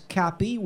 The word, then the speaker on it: Cappy: Also in that same shelter is Cappy, which rhymes with happy.